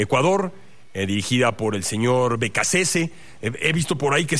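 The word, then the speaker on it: Beccacece: Es de origen italiano. Si fuera él italiano, sería Becacheche. Pero pues los argentinos no suelen pronunciar sus apellidos como originalmente fueron de sus antepasados. Yo me metí a checar antes de que empezara la Copa del Mundo cómo se pronuncia, y se pronuncia como creía, Becasese.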